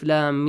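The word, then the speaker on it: Lam (لام): Lam, the name.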